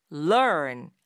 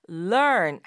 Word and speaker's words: learn: 뛰다라는 뜻의 동사 run, run 하고 learn, learn 하고는 다르죠. R-U-N, 뛰다라고 할 땐 run, run 이렇게 말하지만 좀 짧고 그리고 r 발음이 혀 끝을 살짝 올려서 당기면서 내는 발음이잖아요. run. 근데 그에 비해서